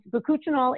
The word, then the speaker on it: bakuchiol: And bakuchinol is actually not a retinol. – Bakuchinol? – Bakuchinol. B-A-K-U-C-H-I-O-L.